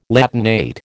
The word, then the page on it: Latinate: American English